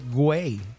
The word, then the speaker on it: Güey: So, in Mexico, I saw a store called Ay Güey. And it means the same thing. It means hurry up or let's go in Spanish. And the G-U-E-Y, G-U is pronounced W in Mexican Spanish.